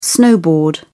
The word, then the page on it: snowboard: English; British English